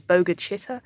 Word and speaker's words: Bogue Chitto: But it does eventually appear that they managed to buy their own land because the community that they lived in, it's called Bogue Chitto, but I think it's pronounced locally as Boga Chitta.